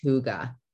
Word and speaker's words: hygge: Now I'm aware you don't say Higgy that way in real life. That's not how the Danish pronounce it, but yes, you get it. I read up on that years back. It's a Danish word that means cozy. And it's technically pronounced hygge.